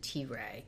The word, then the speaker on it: Teray: How did you pronounce his name? – I pronounced it T-Ray, because T-E-R-A-Y.